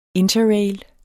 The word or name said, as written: interrail